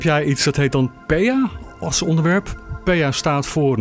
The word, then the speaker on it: PEA: En het wordt eigenlijk gewoon in Nederlands meestal uitgesproken als PEA. – Ja, dus, PEA Pure. – Eigenlijk zijn drie letters, hè, P-E-A. Dus, en dat is de afkorting, maar lekker gewoon PEA. Ja, makkelijker kan het eigenlijk niet.